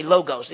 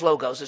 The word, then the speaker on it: logos: Now, the word in the Greek is logos, L-O-G-O-S. Some people say logos. It's not pronounced logos. It's pronounced logos.